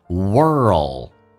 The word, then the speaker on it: world: Kind of person you are， 90% of the world， 90% 的一些世界上的人， world 这个单词很难发，重度卷舌在慢慢的推到你的口腔的那个 L 这个发音，叫做上齿音的地方，叫 world， world， D 可以不发音， world will ignore you。